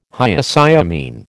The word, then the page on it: Hyoscyamine: American English